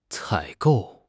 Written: 采购

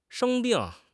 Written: sheng1 bing4

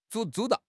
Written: zu1

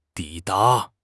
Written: 抵达

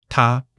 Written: 他

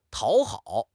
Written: 讨好

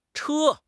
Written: Che1

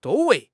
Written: zhou1 wei2

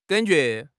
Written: gan3 jue2

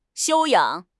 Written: 修养

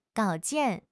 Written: 稿件

